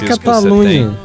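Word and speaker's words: Catalunya: Não, se lê Catalunha, escreve com Y, mas se fala Catalunha assim como nós falamos. – Uhum. – Não, só okay. Que é Espanha. Até onde eu sei, a Catalunha ainda não virou um país.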